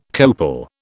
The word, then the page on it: copal: British English